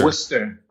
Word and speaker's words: Worcester: Am I saying it right? – Yeah, Worcester. – Mm-hmm. Worcester. – Worcester, Mass.